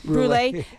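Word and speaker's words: Brûlée: No, oh, Brulee. It's B-R-U-L-E, accent on the U, and then E. So an accent on the E.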